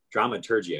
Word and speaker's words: dramaturgy: And we learned in dramaturgy that dramaturgy, I see, keep saying the wrong G pronunciation. – Well, you want to go the German route.